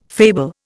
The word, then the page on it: fable: Indian